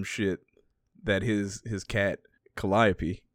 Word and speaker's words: Calliope: So we have Cat we have a street that is spelled Calliope, but it is pronounced Calliope. – Calliope.